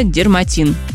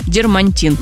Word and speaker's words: дерматин: А вот Леночка нам написала, она не встречала человека, ну, как тут Димка точно вот встретился на вашем пути, который правильно говорит слово дерматин. Дермантин. – Да. – Вот сейчас хорошо, что уже заменили на эко-кожу. Действительно, очень многие говорят дермантин, правильно? – Да, почему-то слово эко, оно...